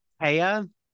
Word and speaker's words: Thea: Yeah, and I think I have to correct a cast member's name, because just with T-H-E-A, I thought it was Thea Gill, but it's not. I think it's Taya.